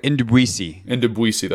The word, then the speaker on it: Ndubuisi: how do you say his name, Ndubuisi? – I'm going with Ndubuisi, that's right.